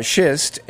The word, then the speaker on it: schist: I almost mispronounced that in a German way, which would be very embarrassing. But anyway, schist, and...